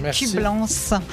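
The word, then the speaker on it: Écublens: Écublance. Je vais prononcer le S comme Leclerc.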